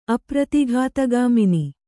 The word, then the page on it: ಅಪ್ರತಿಘಾತಗಾಮಿನಿ: apratighātagāmini